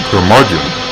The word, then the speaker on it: Curmudgeon: Curmudgeon rhymes with bludgeon.